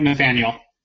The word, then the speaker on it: Nathaniel: Joining me right now, oh, I forgot to ask, how do you like your name pronounced? – I like Nathaniel.